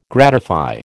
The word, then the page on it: gratify: American English